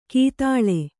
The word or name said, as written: ಕೀತಾಳೆ